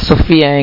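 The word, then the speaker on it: Sofía: E allora, oggi, dopo la proiezione del film Sofia, si pronuncia Sofia in spagnolo, in italiano che è Sofia.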